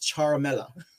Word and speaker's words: Ciarmella: I found out, Two Rivers, Ciarmella is actually pronounced Charmella. And I'm glad I found that out because I was like, wow, okay. So Ciarmella, yes, Charmella, Charmella. It's pronounced Charmella.